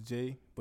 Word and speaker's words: 7AE: His name is pronounced Jay, but it's spelled 7AE.